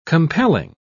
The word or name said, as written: compelling